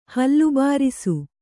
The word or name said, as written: ಹಲ್ಲುಬಾರಿಸು